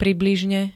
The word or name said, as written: približne